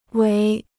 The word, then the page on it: 围: wéi